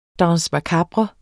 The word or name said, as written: danse